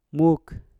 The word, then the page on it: muk: Mpur